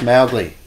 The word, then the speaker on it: Mowgli: Also, Peter, it's Mowgli. Not Moogly, whatever you said. Just so you know. It's supposed to rhyme with cow.